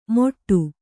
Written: ಮೊಟ್ಟು

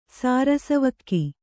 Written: ಸಾರಸವಕ್ಕಿ

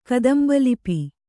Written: ಕದಂಬಲಿಪಿ

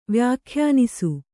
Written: ವ್ಯಾಖ್ಯಾನಿಸು

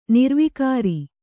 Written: ನಿರ್ವಿಕಾರಿ

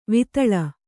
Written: ವಿತಲ

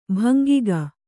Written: ಭಂಗಿಗ

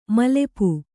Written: ಮೈಲಾಪ

